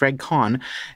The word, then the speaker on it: Regg Cohn: and apparently, yes, it's pronounced Regg Cohn, thinks uh, about Doug Ford.